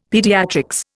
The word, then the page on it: pediatrics: Indian